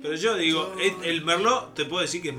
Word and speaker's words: merlot: ¿Vos qué ves que el merlot? ¿Este merlot? – Robert Mondavi. – Merlot. No, pero esto es verdad, no estoy sanateando, ¿eh? La T en el merlot no se pronuncia.